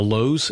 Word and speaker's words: Louhs: We are on Facebook, Twitter, Instagram, and on YouTube, all under the headings of The Lows, and that last name is spelled L-O-U-H-S.